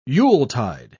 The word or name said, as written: yuletide